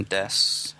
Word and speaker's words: desu: You don't pronounce the U in des, otherwise it makes you sound like.